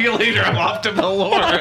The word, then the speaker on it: Belyor: It's two syllables. – Yo, home, smell you later. I'm off to Bel-Yor. – Valir. – No, it's Valir.